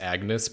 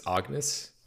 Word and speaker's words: Agnes: I'm glad that you said that we did this before session one, because I was imagining it was pronounced Agnes, but it's Agnes. – Agnes, yeah. It's like a French pronunciation Agnes.